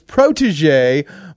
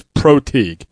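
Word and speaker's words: protégé: And finally, Brian Borland says, could Matt Millen give his insight on how his protege, Martin Mayhew, is doing? – Oh. First off, let me tell you. That word is protege, so you've been saying it wrong.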